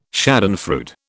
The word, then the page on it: schadenfreude: British English